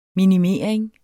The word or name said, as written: minimering